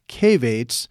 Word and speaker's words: Cavates: It's Mindeleff, M-I-N-D-E-L-E-F-F, Cavates. So it's cave with A-T-E-S at the end of it.